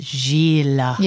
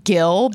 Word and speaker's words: Gilles: When Amelita comes to her rescue, Amelita brings Carrie to the table where she meets Gilles. – Yeah, it's spelled Gil, but it's Gilles.